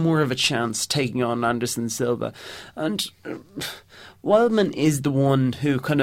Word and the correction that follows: Weidman: Wildman or Wideman? – Wideman. There's an awful pronunciation error in my lifestyle.